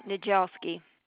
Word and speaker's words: Niedjelski: How do you pronounce that, just out of curiosity? – Nijelski.